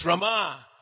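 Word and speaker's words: Ramā: Her name is Rama. We have Lord Rama. Long A first, last A short, and we have Rama.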